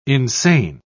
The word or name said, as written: insane